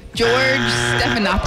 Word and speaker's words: Stephanopoulos: George Stephanopoulos. – Oh, wow. – I can't believe you pronounced that right.